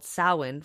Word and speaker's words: Samhain: Samhain from Ireland. – In Gaelic, it's spelled S-A-M-H-A-I-N.